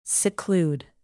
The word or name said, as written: seclude